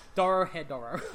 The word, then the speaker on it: Dorohedoro: Which is spelled D-O-R-O-H-E-D-O-R-O. – It's spelled how it's said. – Yeah, Doro Hei Doro.